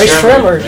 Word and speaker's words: Rae Sremmurd: Yeah, Rae Sremmurd. Is that how you say it? Yeah. Because your drummer is backwards.